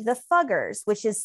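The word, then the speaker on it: Fugger: Is that how you say it? – Yes, it's the Americanized. Like, probably at the time it was Fuggers.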